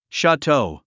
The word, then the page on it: chateau: ʃætóu; シャトゥ